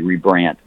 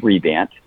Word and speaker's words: Rebandt: And my last name is spelled R-E-B-A-N-D-T. Many people add another R. They call me Rebrandt, but it's just Rebant.